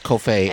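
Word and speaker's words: Coffey: We've been watching on Netflix Country Ever After with Kofay. Is that how you say it? – I believe it is. – Kofay Anderson. – Yes, Kofay Anderson.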